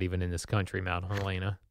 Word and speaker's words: Helena: Is that how you say it? Is it really Helena? – Yes, it is.